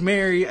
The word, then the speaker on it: Merrie: Guys, there's a character in here with the name of Mary. I thought it was Marie the whole time. – It's spelled M-E-R-R-I-E. – Okay, that sounded like Marie to me, okay? – Okay. – It's okay, it's Mary, I've corrected it in my head.